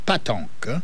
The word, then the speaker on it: pétanque: The Lafayette, Louisiana Pétanque Games starts tomorrow at Girard Park at 2 p.m. Pétanque, or it's pronounced patanque, is a funny French name, but patanque is a bowling game traditionally played in town parks and squares in France on hard-packed surfaces.